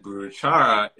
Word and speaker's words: Buruchara: But like the African pronunciation came out as Buruchara.